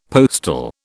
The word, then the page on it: postal: British English